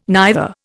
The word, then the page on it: neither: Indian